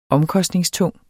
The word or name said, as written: omkostningstung